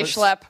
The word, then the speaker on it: schlep: I got a redneck to say schlep.